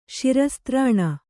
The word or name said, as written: ಶಿರಸ್ತ್ರಾಣ